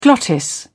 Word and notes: glottis: British English

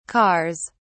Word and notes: cars: British English

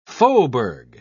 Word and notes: faubourg: British English

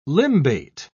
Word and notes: limbate: British English

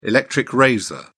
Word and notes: electric razor: British English

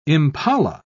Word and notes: impala: British English

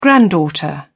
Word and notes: granddaughter: British English

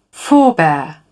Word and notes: forebear: British English

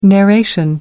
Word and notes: narration: American English

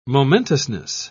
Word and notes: momentousness: British English

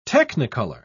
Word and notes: technicolour: British English

